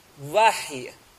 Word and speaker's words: وحی: ہم ہماری زبان میں کہتے ہیں وحی. صحیح تلفظ اس کا کیا ہے؟ وحی. کیا ہے؟ وحی. انشاءاللہ دھیرے دھیرے صحیح ہو جائے گا.